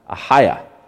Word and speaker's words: Achaia: It's actually pronounced Achaia.